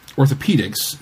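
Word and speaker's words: orthopaedics: I don't know what anachronistic language thing influences these spellings, but Damon's is still pronounced like demons, and orthopedics is still pronounced like orthopedics.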